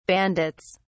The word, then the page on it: bandits: British English